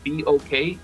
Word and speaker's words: BOK: También nos, gustan las abreviaciones que viene siendo BOK, lo pronunciamos los BOK en inglés, pero son las, las, las iniciales de Boys of Cumbia.